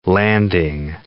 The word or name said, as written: landing